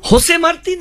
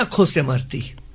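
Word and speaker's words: José Martí: আচ্ছা, কলকাতায় বেশ কিছু বছর এই তর্কটা খুব ছিল, হোসে মার্তি না খোসে মার্তি, কিউবার কবি। আসলে না, এই জে এবং জির উচ্চারণটা খ নয় হ নয়, এর মাঝামাঝি একটা জায়গা, যেটা বলে না সংস্কৃত কিছু উচ্চারণ আছে ভেতর থেকে বেরোবে করে, সেই শব্দটা।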